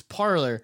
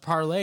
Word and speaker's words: Parler: The founder of Parler says it's parlor because Gavin McInnes was like, oh, it's, well, that's supposed to be pronounced parlay. And he's like, actually, it's just parlor.